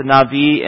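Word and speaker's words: Navi (נביא): A seer is a really synonym of the word Navi, spelled Nabi, but you pronounce it, I guess, in modern Hebrew, Navi.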